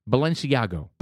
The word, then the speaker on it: Balenciaga: Balenciaga, is that how you pronounce it? – Yeah, you know how to say it. What's the name of that advertising agency? – Balenciaga.